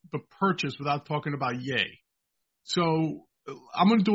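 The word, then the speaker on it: Ye: How do you say his name? Yay as in Kanye West.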